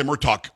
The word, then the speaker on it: Murtaugh: Tim, am I saying that right? You're saying Murtaugh, right? – That's correct, yes. Okay. Like the Lethal Weapon movies.